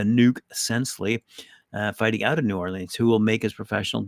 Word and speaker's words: New Orleans: Let's go down to New Orleans, or Nolens, as it's pronounced down there.